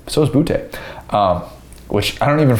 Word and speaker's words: Boutte: if you say it like real Louisiana, it's like Boutte. Like that's that's like you hear Ed Orgeron say that, and then you okay.